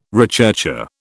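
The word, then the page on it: recherché: British English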